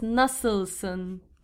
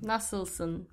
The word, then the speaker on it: Nasılsın: Yeah, well, we have this dotless I, which is kind of difficult for English speakers. – How do you pronounce that I? – The pronunciation would be Yep. It's hard to say, but when it's in a word, maybe it makes, you know, more sense. Nasılsın. – Nasılsın. – Nasılsın, yes.